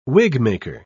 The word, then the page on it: wigmaker: British English